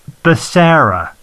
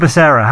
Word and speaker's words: Becerra: I should have probably checked before we start recording, but Richard Becerra. – That's good. That's really good. – Yeah, Richard Becerra.